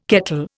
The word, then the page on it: kettle: Indian